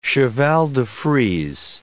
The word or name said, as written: cheval-de-frise